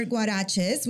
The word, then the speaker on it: Guarexez: So you started your store, Guaraches, which mm-hmm. is spelled G U A R E X E Z, which by the way, the X is a CH sound. Yeah. Ch- ch- sound, which by the way, I was just in Spain and they use the X as a ch sound as well.